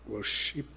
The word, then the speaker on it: Worship: Worship... É isso? Assim que pronuncia? – Isso.